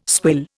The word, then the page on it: swill: Indian